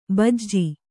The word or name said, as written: ಬಿಜ